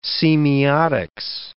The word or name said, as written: semiotics